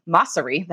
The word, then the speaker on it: Mosseri: Adam Mosseri, that's actually how you say his name, is the head of Instagram.